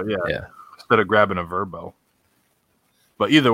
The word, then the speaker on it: VRBO: Now, so because if you look at some of like the Verbos, by the way, I've started seeing commercials for VRBO and they pronounce it Verbo. – Oh, they are Verbos, huh? – Which I don't like. I like VRBO better.